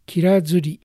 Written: Kirazuri

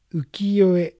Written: Ukiyoe

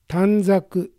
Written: Tanzaku

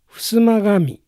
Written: Fusumagami

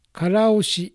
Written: Karaoshi

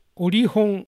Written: Orihon

